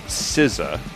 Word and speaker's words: Cisza: This is a band called SZA. It's Polish. – It means silence. Not the pop recording artist, SZA. – Yeah, I was going to say, could you spell that for us? – It's C-I-S-Z-A. And it's Polish for silence.